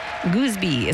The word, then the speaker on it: Goosby: Goosby is spelled G-O-O-S-B-Y, by the way. And to make the pronunciation clear, his Instagram handle is It's Goos.